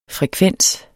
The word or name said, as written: frekvens